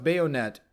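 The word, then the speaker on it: baïonnette: It's bayonet. I see it now. But it's not spelled the way you guys would think. It's B-A-I with a tremol, little dots over it. O-N-N-E-T-T-E.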